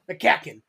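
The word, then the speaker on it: McCaughan: But it kind of made a situation pretty interesting because Darren, help me out here, Darren McCacken. – McCacken, thank you. I always forget it's pronounced like that.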